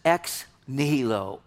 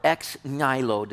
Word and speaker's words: ex nihilo: Theologians call this ex nihilo or ex nihilo, depending on how your Latin teacher tells you to pronounce it.